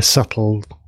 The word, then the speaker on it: Svbtle: I think we can put the URL in the show notes, but it's this blogging platform called, it's like a subtle, but it's spelled S-V-B-T-L-E. So Sierra, Victor, Bravo, Tango, Lima, Echo.